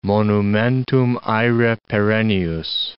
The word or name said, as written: monumentum aere perennius